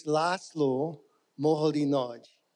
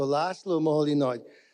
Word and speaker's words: László Moholy-Nagy: So it's László Moholy-Nagy. Okay, so there's no the Z in Hungarian. You don't pronounce the Z. It's almost like it's not there. It's one letter. So László Moholy-Nagy.